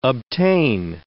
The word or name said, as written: obtain